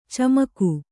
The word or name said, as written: ಚಮಕ